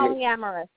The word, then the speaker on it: polyamorous: Moramus? I don't know. Pronounce it for me. – Polyamorous. It's polyamorous.